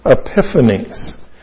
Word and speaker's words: Epiphanes: This is a prophecy of a man whose name was Antiochus Epiphanes. And Antiochus is A-N-T-I-O-C-H-U-S. A-N-T-I-O-C-H-U-S. His second name is Epiphanes. E-P-I-P-H-A-N-E-S. E-P-I-P-H. A-N-E-S.